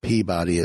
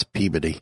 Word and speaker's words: Peabody: Because I know it's not Peabody, it's Peabody. – Yeah. – It's not Worcester, it's Worcester.